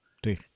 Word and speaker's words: Twig: Y bueno, pues que el, todo el tema de plantilla interna va a ser con, la plantilla de Sinfra, que es Twitch, o Twig, o, no sé cómo se pronuncia. Twig.